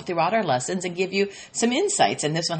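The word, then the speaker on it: eu: So the Greek words bless and blessing come from the root good word, you, E-U, it's pronounced you, which is good, and logos, good word, and the word is logos in the Greek.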